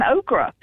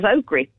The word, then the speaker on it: okra: When I learned how to pronounce it, I just started saying okra. Mm. But growing up, I always thought it was okri.